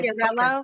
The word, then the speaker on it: Chiarello: Chiarello. – Okay. Chiarello. – But because it's hard for, first of all, the CH is a K sound, so that already throws people off.